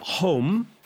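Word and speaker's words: holm: Home, pronounced home, mm-hmm.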